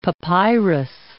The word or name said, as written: papyrus